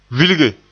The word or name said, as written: vilge